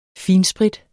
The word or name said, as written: finsprit